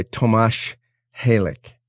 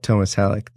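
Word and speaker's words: Tomáš Halík: So I'm uh, reading a book right now by Tomáš Halik, and I mm. just I was just told it's Tomáš Halik, not Thomas Halik, that I used to how I used to pronounce it.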